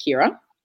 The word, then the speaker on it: Kiara: But looping back around to our new player, her name is says Kiara, but it's actually spelt well pronounced Kira.